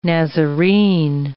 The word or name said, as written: Nazarene